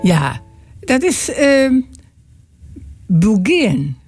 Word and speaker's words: beguine: En je spelt het B-E-G-U-I-N-E. De Bulgin. Zo spreek je het uit.